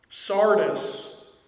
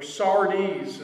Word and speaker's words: Sardis: Sardis, or Sardes is another way that it's pronounced, was the capital city of the area of West Asia Minor called Lydia in its history.